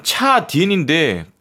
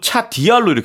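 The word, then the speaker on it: 차 디엔 (茶点): 제가 듣다 보니까 차 디엔인데 한 번은 또 차 디알로 이렇게 또 하셨단 말이에요. – 중국어에서 디엔은 네. 디알이라고 발음하기도 합니다. 아. 즉 얼화시키기도 해요.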